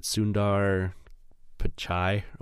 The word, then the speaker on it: Sundar Pichai: Combined with, I can never say his name right, Sundar Pichai or whatever, the Google CEO. – That's pretty close.